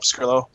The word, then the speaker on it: Pescrilo: You can find me on Twitter at Paul Pescrillo. My last name is spelled P-E-S-C-R-I-L-O.